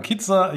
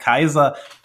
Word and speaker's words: KIZER: Man könnte ja auch sagen Kitzer. Ich hatte schon zum Eingang, als wir kurz gesprochen haben, es ist ja ähnlich wie bei Pfizer oder Pfizer. Wie wird es mhm. denn jetzt ausgesprochen? Kaiser ist die richtige Aussprache.